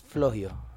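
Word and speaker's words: Flohio: Y ese ya es Flogio. Sí, así es como se pronuncia, una chava inglesa.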